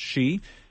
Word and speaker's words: Hsu: His last name is H-S-U, but it's pronounced Shee.